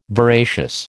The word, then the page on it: veracious: American English